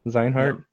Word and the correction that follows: Xehanort: It's Xehanort. Xehanort. Xehanort. Xehanort. Xehanort. Xehanort. Xehanort. Yes. Xehanort. X E H A N O R T.